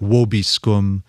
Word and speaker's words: vobiscum: Where you say the V in classical Latin, it'd be more like vobiscum.